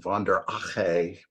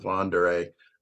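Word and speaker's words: von der Ahe: Some people pronounce it von der Ache, but I just go with von der Ey.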